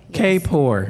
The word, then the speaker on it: Kapor: So for the past few years, Kalisha Davis with the Kapoor Center. Am I saying it right? – Kapoor, because I'm self-conscious about how to say this.